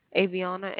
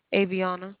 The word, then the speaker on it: Avione: Aviana, A-V-I-O-N-E. – A-V-I-O-N-E. N-E. How do you pronounce it? – Aviana.